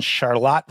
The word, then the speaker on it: Charlotte: A little bit different pronunciation than the North Carolina Charlotte. – Yeah. I think they're named after the same King George III's wife, Queen Charlotte or Charlotte. But for some reason, we maintain the German pronunciation.